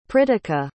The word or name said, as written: pritika